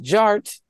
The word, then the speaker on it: DJarTT: And my name is Jared, aka DJ Art, with two T's for a double dose of that tink tink. The D is silent, so it's just Jart.